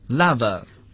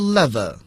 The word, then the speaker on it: lava: lava 也可以读作 lava 或者是 lava 表示熔岩火山喷发之后形成的一种地形结构所以通常我们说某地的地形结构是熔岩地形我们可以说 in the form of lava